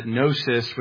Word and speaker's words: gnosis: And it makes sense because in Greek, pro just means before and gnosis with a silent G there means knowledge.